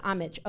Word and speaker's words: homage: So I really wanted to try and pay homage. Is that how you pronounce that word? Or it's That's homage. How I pronounce it. – It is homage. Okay. Yeah. Sometimes I think it's homage.